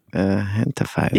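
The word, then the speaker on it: Gentefied: He can be seen in the very funny Hulu series, This Fool, and in the hit Netflix series, Hentified. Everybody, please welcome Francisco Ramos. – Thank you. – Thank you so much for joining me today. – I like that you also pronounce it Hentified.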